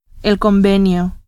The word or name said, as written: convenio